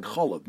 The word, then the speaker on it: cholav: The word is pronounced, as I said, cholav, milk.